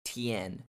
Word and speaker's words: teian: One of the best uses of Kaizen in business is what's commonly used in Japan, and it's called the Kaizen Tien, which is spelled T-E-I-A-N.